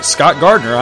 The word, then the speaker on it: Scott Gardner: And hey, you can friend me, Scott Gardner, on Facebook too.